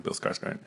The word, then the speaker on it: Skarsgård: He like took vocal training to sound like Bill Skarsgård.